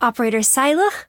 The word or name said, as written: Saileach